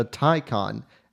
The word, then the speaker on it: Taycan: Today we're talking all things Porsche Taycan. And yes, that's how you say it.